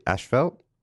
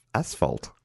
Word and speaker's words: asphalt: We did get a bit of feedback after a recent episode about how we say Ashfelt. – Yeah. – And I looked into it, and it looks like it's, for some reason, Australia and Canada is majority of people say Ashfelt, but we say it wrong. There's no H in that spot. It's asphalt.